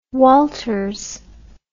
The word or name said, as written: Walters